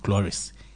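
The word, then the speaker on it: chloris (χλωρός): That's, but then in pronouncing is that we say it chloris.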